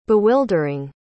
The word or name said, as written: bewildering